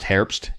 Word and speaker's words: Herbst: It's pronounced Herbst, and it means autumn.